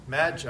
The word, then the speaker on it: Magi: titled this message The Ma- Magi. I think that's pronounced correctly, like magic.